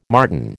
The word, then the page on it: martin: American English